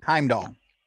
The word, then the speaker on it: Heimdall: Heimdall.